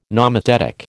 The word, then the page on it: nomothetic: American English